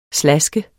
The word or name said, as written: slaskede